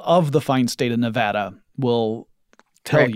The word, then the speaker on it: Nevada: So I went to CES 2019 in Las Vegas, Nevada, which I have learned is pronounced Nevada. Not Nevada.